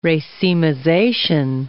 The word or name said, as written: racemization